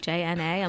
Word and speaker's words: J'na: They're just like, so your name is like spelled like that, like J N A.